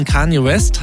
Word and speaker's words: Kanye West: Nach der Trennung von Kanye West habe ich es richtig ausgesprochen? – Ja.